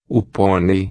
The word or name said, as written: ponei